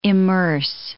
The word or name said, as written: immerse